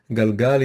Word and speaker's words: galgal: That's why it's an actual sort of round word, because it's one syllable repeated, galgal, and you can repeat it endlessly, galgal, galgal, galgal, just like a wheel that turns endlessly.